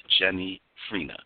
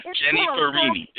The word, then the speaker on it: Jennifrini: Jenny Frina. Did I say it right? Jenny Farini. – Kind of like a martini, coffee teeny with Jennifer Aniston.